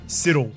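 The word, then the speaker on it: Siddal: I think I called the town Siddell. – Yeah. – It's pronounced. – Siddle? – Siddle. – Siddle? – Siddle. – All right. – As in like the bowler. The bowler. – Fast, medium. – Peter Siddle? – Peter Siddle. Yeah. Pronounced like that.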